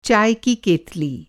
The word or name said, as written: केतली